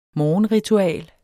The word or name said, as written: morgenritual